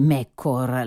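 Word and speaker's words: Meccore: E quindi, grazie all'anonimo ascoltatore che con molta dovizia di dettagli ha indicato come Mekkor la giusta pronuncia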